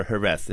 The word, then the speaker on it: Jerez: We call it sherry or Jerez or Jerez is how it's pronounced in Spain.